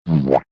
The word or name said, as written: boo womp